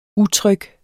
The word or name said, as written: utryg